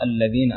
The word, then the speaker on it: الذين: وكذلك في حرف الذال الذين أيضا كان ينطق بحرف الزاي، والصحيح إخراج اللسان، لأن هناك فرق بين الزاي والذال، الذال يكون فيه إخراج اللسان، فتقرأ الذين أنعمت عليهم.